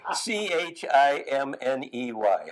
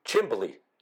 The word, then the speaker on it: chimney: What was uh, C C H I M N E Y? How do you say that? Chimney. – No, Chimbley.